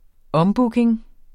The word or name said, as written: ombooking